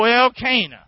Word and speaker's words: Elkanah: Oh, Elkanah. Now, I don't know how you say it. I know if you're from somewhere else, you'd pronounce it different. But it's my message, so he's Elkanah.